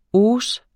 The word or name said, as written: hausse